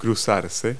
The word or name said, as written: pass